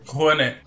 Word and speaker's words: Rouanet: que nesse mesmo ano houve um corte de, de dinheiro para a lei Juanu. é Rouanet ou Juanu? Eu tô falando certo? Rouanet. – Rouanet, é, certo? Isso.